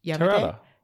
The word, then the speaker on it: Terada: Could you pronounce Terada again? – Terada? – Sounds like you have to pronounce Terada properly, otherwise like a Turada.